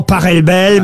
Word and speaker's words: Pachelbel: S'il est allemand, ça se dit Pachelbel. – Exactement, ouais. Ça pourrait se dire éventuellement Pachelbel, mais on dit en tout cas, ici en France, le canon de Pachelbel.